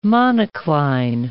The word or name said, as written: monocline